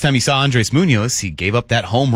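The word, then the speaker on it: Muñoz: Yes, Munoz. – Munoz. – Dave Valle likes to call him, and I think he deserved that extra syllable. – The proper pronunciation there, by the way.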